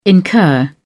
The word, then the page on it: incur: British English